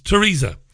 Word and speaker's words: Theresa: You're not supposed to pronounce the TH when you say Theresa May, for whatever reasons. Bizarre, really. It's Theresa.